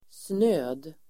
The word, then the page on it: snöd: [snö:d]